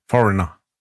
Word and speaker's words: Foreigner: Ich weiß Foreigner. es nicht. – Foreigner.